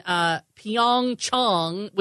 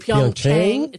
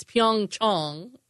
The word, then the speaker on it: Pyeongchang: Everybody's saying Pyeongchang. It's Pyeongchang. – According to my South Korean friends. We're all saying it wrong.